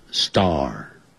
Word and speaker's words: star: 是就我们讲的月亮 Moon， Moon 中午叫 Noon， Noon月亮 moon moon 月球，哎，别忘了太阳怎么说 sun sun，最后我才讲这个字 star star 星星，哇，天上有好多星星啊， there are many stars in the sky，不要管它，这些字都不要管，我们只要学会 star，因为 S-T-A-R 有一个 R 的音，结尾的时候是要卷舌的，而且 S 后头有那个 T 的时候，可不要念成 star，那个 T 就变音了，变成的的音 star，以后你要看的另外一个字叫 S-T-O-P，停下来就不念成 stop 就不对了， stop，总而言之 S 后头有 T 的时候一定要念成 the star，星星 star，许多星星呢 stars 就加个 S stars。